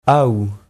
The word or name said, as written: aou